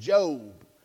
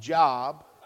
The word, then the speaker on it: Job: Last time I was here, I was preaching out of the book of Job. Half y'all couldn't find it because you thought it's pronounced Job.